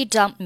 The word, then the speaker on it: dumped: In fact, she dumped me. – Dump 加上 ed, 它读音是 dumped. – Dumped. – 那在句子当中呢，这个 dumped 末尾的 p 和 t 都可以失去爆破，不用发音。